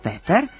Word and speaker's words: Petar: Po ruskom modelu Petar i po ukrajinskom izgovoru Petro.